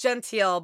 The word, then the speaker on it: Gentile: It's Gentile, but I've had 3 people my whole life maybe pronounce it right.